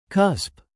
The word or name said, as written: cusp